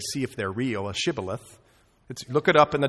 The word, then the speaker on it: Shibboleth: And if he said no, then they would say to him, then say Shibboleth. And he would say Sibboleth. In other words, they caught them by their accent. Thank you. They caught them by their accent, for he couldn't pronounce it right, and they figured out who the Ephraimites are.